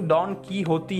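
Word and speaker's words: Don Quixote: वहाँ पे इसका प्रोनाउंसिएशन डॉन की होती है आई गेस और यहाँ पे हम लोग क्विक्जॉटिक भी बोलते हैं तो दोनों प्रोनाउंसिएशन चलता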